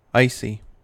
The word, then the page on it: icy: American English